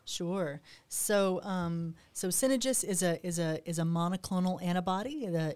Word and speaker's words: Synagis: What about Synagis? Mm-hmm. Am I saying that right? – Synagis, yeah.